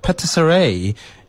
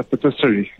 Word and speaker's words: patisserie: Patisserie in Johannesburg. Good afternoon to you. – Hi, how are you? – Wonderful, wonderful. So is that how you pronounce it? Is it a patisserie? – It's a patisserie.